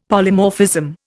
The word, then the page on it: Polymorphism: Indian